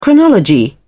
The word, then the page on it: chronology: British English